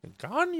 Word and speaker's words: Gagnon: Man, I'm usually on top of Mets spellings and pronunciations, but just think about how Adam Sandler would say his name. that's the clue there. Gagnon, you know. that's the way to pronounce it.